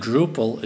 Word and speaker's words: Drupal: and Drupal is how an English-speaking person would probably pronounce the Dutch word for droplets.